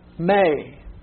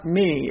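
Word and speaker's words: may (μή): That is not me. It looks like me in English, but you have a long E. That what you see is two letters. You have a mu, which is the M, and then you have an eta, and it is a long A sound. So it would be may.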